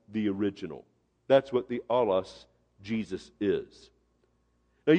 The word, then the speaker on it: allos: The word another here is the Greek word allos, spelled phonetically A-L-L-O-S, allos.